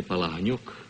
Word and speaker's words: Palahniuk: Actually, my name is Vladimir Palahniuk. But why didn't you tell me? – I pronounce it Jack Palance. – Palance. – Mm-hmm. How did you happen to pick that name? – Jack Palance? – Yeah. – Oh, it was sort of one of those things, Palahniuk. Nobody could pronounce it. They said, well, we'll call you Palansky, and from Palansky I took Palance. But the name is Palahniuk.